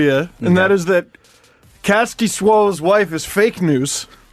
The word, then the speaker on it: Kaskisuo: She said, "Hey, Steve Dangle, I just met Kaski Suo's family. They're super sweet. And I asked how to pronounce his name. You're saying it right!